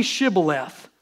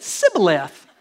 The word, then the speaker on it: shibboleth: And when he said, No, no, no, no, they said to him, Well, then say Shibboleth. This is weird, y'all. This is cool. – And he said, Sibboleth. For he could not pronounce it right.